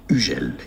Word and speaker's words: Hugel: Hugel is the French pronunciation. That's the sound of the letter U. U, very French. The H is silent. And then Hugel, not Hugel. Hugel.